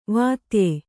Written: ವಾತ್ಯೆ

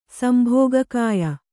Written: ಸಂಭೋಗಕಾಯ

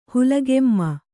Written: ಹುಲಿಗೆಮ್ಮ

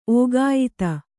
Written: ಓಗಾಯಿತ

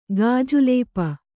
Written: ಗಾಜುಲೇಪ

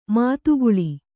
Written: ಮದಕಲ

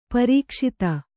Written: ಪ್ರಕಾಶಿತ

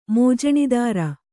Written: ಮೋಜಣೀದಾರ

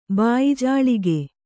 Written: ಬಾಯಿಜಾಳಿಗೆ

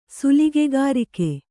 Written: ಸುಲಿಗೆಗಾರಿಕೆ

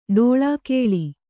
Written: ಡೋಳಾಕೇಳಿ

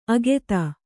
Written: ಅಗೆತ

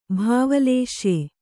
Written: ಭಾವಲೇಶ್ಯೆ

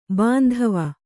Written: ಬಾಂಧವ

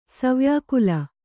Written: ಸವ್ಯಾಕುಲ

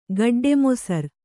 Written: ಗಡ್ಡೆಮೊಸರು

